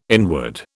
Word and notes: inward: British English

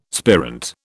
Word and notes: spirant: British English

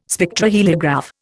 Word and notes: Spectroheliograph: Indian

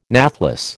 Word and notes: nathless: American English

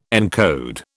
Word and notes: encode: British English

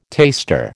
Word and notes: taster: American English

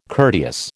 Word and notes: courteous: American English

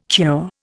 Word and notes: cure: Indian

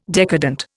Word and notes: decadent: Indian

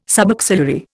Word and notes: subaxillary: Indian

